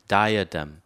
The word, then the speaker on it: diadem: 자, 이것은 diadem이라고 읽어야 됩니다. 자, 발음을 잘못 읽어서 제가 인터넷에 소리를 따왔습니다. diadem.